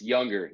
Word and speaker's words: Junger: It's Younger. Yeah, it's Younger. Spelled Younger, but pronounced Younger.